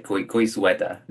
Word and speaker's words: Goizueta: Goizueta? It's a little bit more of a quiz kind of thing in there? – It's like sweater, but Goizueta. – Goizueta.